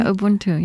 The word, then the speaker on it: Ubuntu: the virtue of Ubuntu. Am I pronouncing it correctly? – Oh, yeah, Ubuntu, yeah. – Ubuntu.